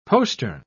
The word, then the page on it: postern: British English